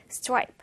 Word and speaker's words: stripe: 자, 우리말로는 흔히 스트라이프 이렇게 다섯 음절로 이야기를 하지만 영어 발음은 stripe. stripe로 된다라는 거 기억하면 좋겠습니다.